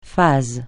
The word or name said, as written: phase